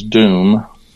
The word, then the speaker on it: dome: There's dome, D-O-M-E, which you're pronouncing as doom, and it's a Saxon word, and it references it as doom, D-O-O-M, as in Mary.